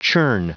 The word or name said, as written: churn